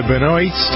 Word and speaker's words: Benoist: The star of the show is Melissa Benoist. I think that's how you pronounce her name.